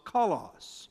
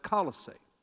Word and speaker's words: Colossae: Epaphras was from Colossus. Some people pronounce it Colossae.